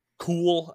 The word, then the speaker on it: Kuhl: But Chad Kuhl, I think is how you say his last name, K-U-H-L.